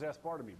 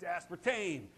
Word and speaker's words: aspartame: How do you say that word? Aspartame. Aspartame with an M. Okay. I thought it was aspartame, but it's aspartame.